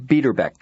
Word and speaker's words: Biederbek: George Biederbeck. – Could you spell that for me, please? – B-I-E-D-E-R-B-E-K.